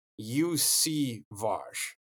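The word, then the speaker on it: Iosivas: And by the way, as far as the pronunciation, he actually changed it like while we were there, because before it was like in the pronunciation guide at Princeton, it was Yoshivas. And so that's how I was pronouncing it. And then he corrected us and said it was UC Vosh, I think is, but anyway, we got a couple months to work on that.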